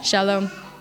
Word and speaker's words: Shalom: Shalom.